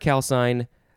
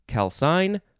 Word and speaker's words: calcine: It is the word calcine. You could pronounce it either calcine or calcine.